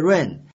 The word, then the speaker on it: reign: 注意前面两个单词啊，一个是 reign 君主统治，或者是当政统治的意思，以及这个 reign 缰绳、勒缰使马停步，还有一个 rain 就是下雨，它们的发音都是一样的，我们一定要区别记忆这几个单词。